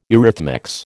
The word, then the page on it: eurythmics: American English